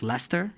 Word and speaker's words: Leicester: It's called pronounced Leicester.